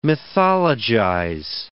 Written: mythologize

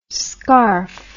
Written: scarfe

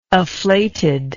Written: afflated

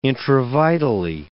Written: intravitally

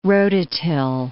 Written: rototill

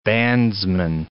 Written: bandsman

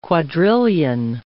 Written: quadrillion

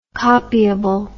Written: copiable

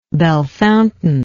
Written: Bellefontaine

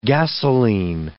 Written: gasolene

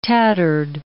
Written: tattered

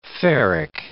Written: ferric

